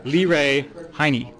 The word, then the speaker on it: Leray Heyne: Liray Haynes? Leray Heyne. Lee Ray. Well, I got it totally wrong. – Lee Ray Heyne.